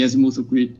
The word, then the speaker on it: azimuthal: He says, azimuthal. Am I saying that right? Have I been saying it wrong this whole time? – Azimuthal. – Azimuthal. Very embarrassing.